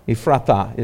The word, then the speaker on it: Ephrathah: Ephrathah is how that's pronounced.